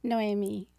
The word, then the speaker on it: Noemi: Today on the show, I have the amazing, and I always say your name wrong, but it sounds like Naomi, but it's spelled like Noemi, but it's actually pronounced Noemi.